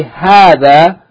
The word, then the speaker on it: هذا: The first note, هذا is pronounced as though it's ha fatta alif, thal fatta alif, with two alifs, but it is written without the first alif. It's pronounced hadha, but the first alif is not written.